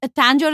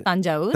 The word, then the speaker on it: Tanjavur: Tanjore is the British pronunciation of Tanjavur.